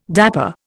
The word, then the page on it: dapper: Indian